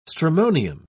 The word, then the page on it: stramonium: British English